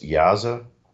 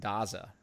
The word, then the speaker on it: Daza: Is it pronounced Yaza? – Oh, you're talking Jonathan Daza, right? – Yeah.